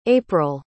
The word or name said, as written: aprille